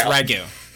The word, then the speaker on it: ragout: It's Ragu. Yes, it is spelled like Ragout, but... as with most French words, it's not pronounced the way it looks.